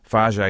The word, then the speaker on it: Faget: Fage is spelled F-A-G-E-T, but said Fage.